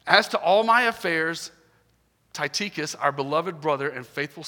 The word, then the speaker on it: Tychicus: And his name is pronounced Tychicus.